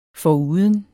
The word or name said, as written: foruden